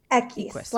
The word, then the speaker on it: Equis: Is it Equis? – Equis, like the letter Equis. – Oh, Equis, like Dos Equis. Oh, wow. – Yes, okay. One X, not two.